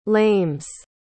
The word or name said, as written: lames